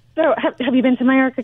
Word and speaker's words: Mallorca: All right, let's move along to Mallorca. Am I saying it correctly? – Yeah.